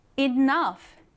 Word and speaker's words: enough: enough. 중요한 단어예요. 자주 나옵니다. enough라고 하면요. 충분한 혹은 충분히라는 의미를 가지고 있어요. 발음이 조금 특이하죠? enough에서 F 발음이 나게 됩니다.